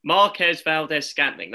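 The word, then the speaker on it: Marquez Valdes-Scantling: Marquez Valdez Scantling.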